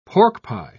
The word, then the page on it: porkpie: British English